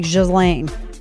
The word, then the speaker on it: Ghislaine: And everyone's like, how do you pronounce Ghislaine? And apparently it's something like Ghislaine.